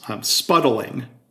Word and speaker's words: spuddling: As you know, you talk about, and I'm learning, okay, it's three syllables, spuddling. – Spuddling, yeah. – I tried to make it two syllables, spuddling, but spuddling.